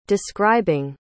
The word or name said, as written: describing